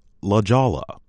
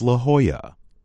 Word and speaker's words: La Jolla: This sun-drenched town is commonly mispronounced La Jolla, but the correct pronunciation is La Jolla.